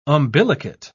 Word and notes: umbilicate: British English

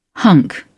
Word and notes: hunk: British English

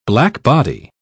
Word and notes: blackbody: British English

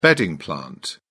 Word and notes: bedding plant: British English

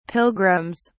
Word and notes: pilgrims: British English

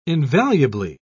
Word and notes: invaluably: British English

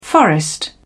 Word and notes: forest: British English

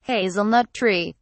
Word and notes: hazelnut tree: British English

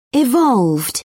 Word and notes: evolved: British English

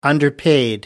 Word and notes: underpaid: British English

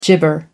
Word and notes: gibber: American English

